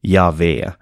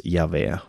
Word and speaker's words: Yahweh: Det är fyra bokstäver, J-H-V-H, som av de flesta bibelforskare anses uttalas ungefär Yahweh. På hebreiska så hade man bara konsonanterna och sen fick man själv lägga in vokalerna. Så är det i många språk. Men de här fyra bokstäverna då, J-H-V-H, antagligen ska det uttalas Javé.